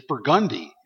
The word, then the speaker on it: Burgundy: Like Burgundy Street is Burgundy in New Orleans.